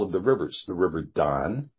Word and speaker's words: Dan: Dan isn't pronounced Dan. It's pronounced Don.